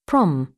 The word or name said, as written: prom